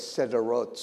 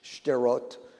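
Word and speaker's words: Sderot: I'll say it's Sederot, so it sounds like it's spelled S-E-D-E-R-O-T, but Shderot, Strot, you know, there's usually the way it's said by Israelis.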